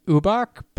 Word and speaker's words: Ubach: I said Ubach, but it's U-B-A-C-H.